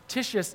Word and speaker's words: Titius: Then Paul, he left the synagogue, like the spiritual center, and he went next door to the house of Titius is how you pronounce that.